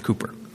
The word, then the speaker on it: Cowper: You say, no, it isn't, it's Cowper. Well, it's spelled that way, but it's pronounced Cowper.